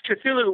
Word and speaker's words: Cthulhu: Yes, Cthulhu.